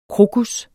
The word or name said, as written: krokus